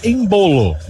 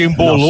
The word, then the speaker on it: Embolo: Olha, Pedro, ele fala Embolo, mas há várias pronúncias de Embolô.